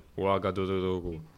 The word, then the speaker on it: Ouagadougou: see on tegelikult pealinn ja Ogodogonogo on. see ei olnud õige hääldus. Ogodogonogo,